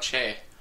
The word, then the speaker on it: Che: I think it's Che? C-H-E? – Oh, you're talking about Che. – Yeah, Che.